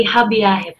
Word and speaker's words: Hobbyahe: Paano ba i-pronounce ang iyong napakagandang business na ito? – Just say Hobbyahe po. – Ayun, Hobbyahe.